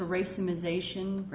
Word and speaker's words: racemization: racemization. Did I even say that right? – Yes, you do.